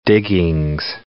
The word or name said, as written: diggings